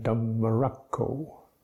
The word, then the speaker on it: Dhammarakko: The effect is one of lingering on the consonant, such as Ajahn Dhammarakko. Dhammarakko. You linger on the consonant rather than Dhammarakko. So the lingering on the consonant gives a certain weight to the syllable.